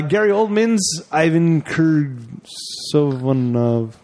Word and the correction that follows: Korshunov: What? You want me to spell it for you? – Hold on. Korshunov? Ivan Korshunov. Ah.